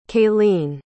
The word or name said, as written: kaylene